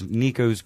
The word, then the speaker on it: Nico's: We kept saying it wrong for a couple weeks. It's Nico's Greek Kitchen in the West Street Market.